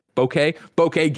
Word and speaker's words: Bokeh: Bokeh Game Studio.